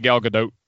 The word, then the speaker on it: Gadot: Apparently they're supposed to say the T. I've said it wrong for years, apparently. – Well, how would you say it? – I think it's supposed to be Gal Gadot.